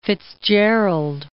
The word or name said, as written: Fitzgerald